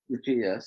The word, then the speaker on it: Tucci: Thankfully, Mike Tobin has managed to bring in Stanley Tucci, right? Is that how it's pronounced? – Tucci, yes.